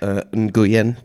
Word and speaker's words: Nguyen: So it is recognized by the Vietnamese Ministry of Transportation as the first domestically made electric bicycle, and Mr. Nguyen, who owns the company, it's pronounced Nguyen.